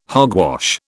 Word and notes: hogwash: British English

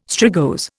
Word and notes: strigose: Indian

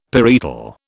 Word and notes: parietal: British English

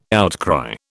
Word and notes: outcry: British English

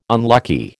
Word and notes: unlucky: American English

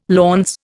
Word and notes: launce: Indian

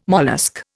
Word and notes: mollusc: Indian

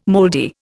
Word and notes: moldy: Indian

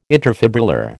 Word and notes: interfibrillar: American English

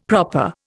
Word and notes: proper: Indian

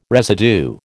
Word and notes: residue: American English

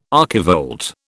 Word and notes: archivolt: British English

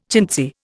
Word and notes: chintzy: Indian

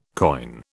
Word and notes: koine: British English

